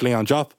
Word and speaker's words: Leon Diop: If you also want to find me on my personals, just Leon Diop, I suppose I'm actually going to pronounce my name properly. It's Leon Diop.